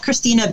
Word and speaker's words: Krisztina: And then, of course, my name is spelled with a Z in there, that K-R-I-S-Z-T-I-N-A. But at Christina Bell on Facebook and Instagram, I'd love to, hey, I like to collaborate with whoever.